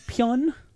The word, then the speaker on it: Pyun: His name is P-Y-U-N. Pyun? Albert Pyun. – Oh, no, you're pronouncing it wrong. It's Albert Pyun.